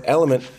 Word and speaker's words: LMNT: So he kept saying, I put element in my water, but uh, I couldn't find it when I searched it. It's because it's L M N T. It's spelled L, the letter L M N T.